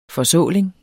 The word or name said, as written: forsåling